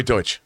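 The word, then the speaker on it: Deutch: Zoe, how do you say her last name? Deutsch? Dutch? – Yeah, Zoe Deutsch.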